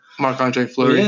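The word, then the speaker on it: Marc-André Fleury: Fleury. I don't know how to pronounce his name. – Marc-Andre Fleury.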